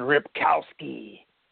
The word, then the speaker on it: Ripkowski: So this really isn't a great matchup for their running backs, Montgomery, Michael, and Ripkowski. That's how you got to say his name.